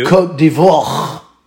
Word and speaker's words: Côte d'Ivoire: Côte d'Ivoire. Because they don't really pronounce the R.